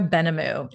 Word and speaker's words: Benhamou: It's Zora Benhamou. It's a French last name, and that's my husband's name. And it's the H is silent, but Zora the Explorer is always so much easier to remember.